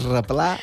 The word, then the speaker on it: replà: I m'ha agradat molt perquè ho ha pronunciat en bon mallorquí, el replà.